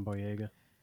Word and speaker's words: Boyega: Yeah, John Boyega.